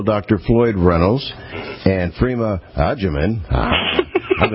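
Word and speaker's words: Agyeman: Adjaman. Phonetically figure that out like we would use in Italian, agita.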